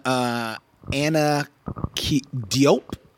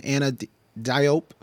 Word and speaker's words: Anna Diop: And that spark would be one Anna Diop, I believe is how you pronounce her name. D-I-O-P is her last name. Anna Diop, who is playing Starfire in Titans.